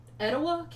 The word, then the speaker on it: Etowah: Meanwhile, 700 miles away in Etowah County, is that how you say it? – Mm-hmm. – In Etowah County, Alabama, another mystery had developed.